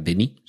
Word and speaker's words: Denis: Esta semana una noticia muy esperanzadora y es que Denis Villeneuve, que en realidad creo que se pronuncia Denis, sin, sin pronunciar la S,